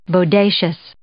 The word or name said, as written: bodacious